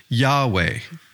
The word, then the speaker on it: Yahweh: Well, we know now that the name is pronounced Yahweh.